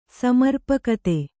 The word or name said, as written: ಸಮರ್ಪಕತೆ